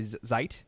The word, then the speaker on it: Zeit: Although for my actual applications, I tend to use now by Zeit, not Zeet, as I've been mispronouncing it for years.